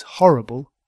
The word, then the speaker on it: horrible: But a horrible story, because horrible has the stress, horrible, on the first syllable.